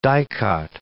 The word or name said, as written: dicot